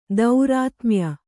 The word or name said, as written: ತಾರತಮ್ಯ